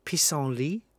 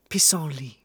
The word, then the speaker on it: pissenlit: I'm gonna take a stab at the pronunciation of pissenlit. P-I-S-S-E-N-L-I-T.